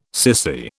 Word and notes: sissy: British English

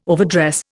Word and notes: overdress: Indian

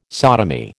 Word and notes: sodomy: American English

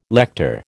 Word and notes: lector: American English

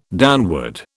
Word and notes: downward: British English

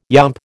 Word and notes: yomp: American English

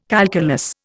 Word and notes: calculus: Indian

